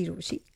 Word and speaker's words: Rochie: मेरा नाम है मिर्ची रोशी, काम है आपसे प्यार करना, नाम की स्पेलिंग है ROCHIE.